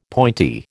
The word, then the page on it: pointy: American English